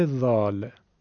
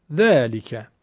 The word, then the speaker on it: ذال: حرف ذال عیناً مثل حرف ث سه نقطه از تماس نوک زبان و دندونای بالا با صدای نازک و کم حجم تلفظ میشه. این خیلی مهمه که حرف ذال رو باید نازک بگیم، مبادا درشت بگی ذالکه.